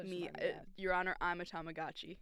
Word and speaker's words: Tamagotchi: Tamagotchi. – Tamagotchi.